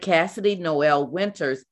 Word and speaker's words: Kassidie Noel Winters: So you said Kassidie Noel Winters is trying, is tired of living behind shattered glass.